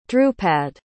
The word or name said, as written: drupad